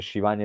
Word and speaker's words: Schiavone: Perché si dice Shivani e si scrive Schiavone? Questo non lo so, però Shivani, Ma sì, Tony Schiavone, Schiavone.